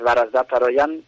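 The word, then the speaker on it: Varazdat Haroyan: Para nosotros es un desconocido, Barazdad Aroyan, que lo primero, ¿se dice así bien? ¿Lo digo bien? ¿Lo pronuncio bien el nombre así? – Sí, sí, está muy bien, es Barazdad Aroyan, está muy bien.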